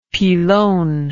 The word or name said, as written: pilon